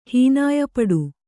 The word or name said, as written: ಹೀನಾಯಪಡು